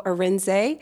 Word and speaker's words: Arinzeh: As you correctly pronounced, my name is Ugo Arinzeh, and I am founder and managing director of Onyx Property Consultants.